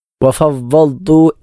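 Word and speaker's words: വഫവ്വൽതു (وفوضت): ഇവിടെ ഉച്ചാരണത്തിൽ ശ്രദ്ധിക്കേണ്ട അക്ഷരം വഫവ്വൽതു എന്നുള്ളിടത്താണ്. വഫവ്വൽതു എന്നുള്ളിടത്ത് ദാദാണ്. ദാദ് എന്ന അക്ഷരം നാവിൻ്റെ ഇടതുഭാഗം ഇടതു പല്ലുകളിലോ അതല്ലെങ്കിൽ വലതുഭാഗം വലതു പല്ലുകളിലോ സ്പർശിക്കും തരത്തിൽ വായ നിറച്ച് ഉച്ചരിക്കേണ്ട അക്ഷരമാണ് ദ്വാ.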